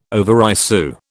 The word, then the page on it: overissue: British English